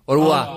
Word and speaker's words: Au revoir: Arrebois, ¿no? – Au revoir.